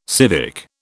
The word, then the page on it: civic: British English